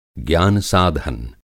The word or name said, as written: ज्ञान-साधन